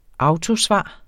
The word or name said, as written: autosvar